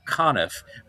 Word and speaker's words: Conniff: Frank Conniff, rhymes with Goniff,